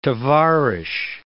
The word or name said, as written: tovarish